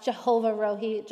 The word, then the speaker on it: Jehovah Rohi: The phrase, "The Lord is my shepherd," is Yahweh Rohi in Hebrew. You may have also heard it pronounced Jehovah Rohi. Jehovah and Yahweh are just two different ways of pronouncing the personal name for God.